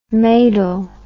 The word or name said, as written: maidel